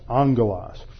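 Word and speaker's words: angelos (ἄγγελος): So it is uh, pronounced angelos.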